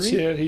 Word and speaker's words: Thierry: But I know, and I forget his family, Terry will be there for sure. Or Thierry? – Thierry, you see. – Pronouncing that name in English is a pain because it doesn't really exist.